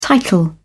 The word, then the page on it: title: British English